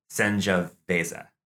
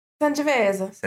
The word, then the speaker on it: Sangiovese: Which I'm endeavoring to like use the correct pronunciation. You know, I like to enunciate every syllable. – Do it. – So actually it's Sangiovese. – Sangiovese.